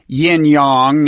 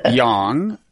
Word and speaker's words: yang: Now, Americans are probably accustomed to pronouncing that pair of syllables, yin yang. That's the way it's known here. But I learned from you that the correct pronunciation is yang. – Yes. – Y-A-N-G.